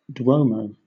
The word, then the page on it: duomo: British English